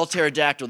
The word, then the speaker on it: pterodactyl: Okay, so why can't you hear when a pterodactyl goes to the bathroom? Their P is silent. You have to know how to spell pterodactyl, though. It does start with a P, but it doesn't sound like it.